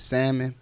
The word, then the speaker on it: salmon: If you've never had Muffy's salmon, The L is silent for those who don't know.